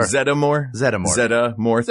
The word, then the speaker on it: Zeddemore: Zeddemore. Three syllables? – Yeah.